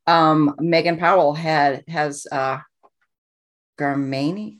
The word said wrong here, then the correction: Garmiani: I'm not sure if I'm pronouncing this right. Garmiani. Garmiani. Thank you.